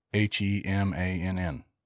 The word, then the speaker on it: Hemann: H-E-M-A-N-N.